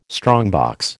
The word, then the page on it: strongbox: American English